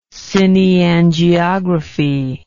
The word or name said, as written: cineangiography